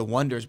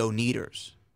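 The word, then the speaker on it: Oneders: The band's name is The Wonders, but they spell it with O-N-E. – Okay. – Durs. Like the Beat Ulls, right? – Got it. – But everyone would always say the O-Neaters. Instead of Wonder, because they would read it and be like, oh, they're the O'Neaters.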